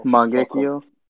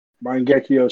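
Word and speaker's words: Mangekyō: So Mangekyo. – Mangekyo Sharingan.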